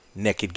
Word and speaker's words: Nekkid: We are on Instagram at The Cigar Pulpit and now Naked Gator, N-E-K-K-I-D, Gator. I always have to spell it because you pronounce it so like Southern Illinois, Nick, you know, so I figure I have to properly spell it.